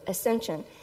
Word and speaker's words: Ascension: Ascension. And so that word is right here on your children's bulletin. Ascension, that's how you spell it. That's how you pronounce it.